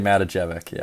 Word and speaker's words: Matijevic: I think I saw, I can't say his name, Matjevic. Matjevic. – JJ Matijevic, Matijevic, yeah, I am not.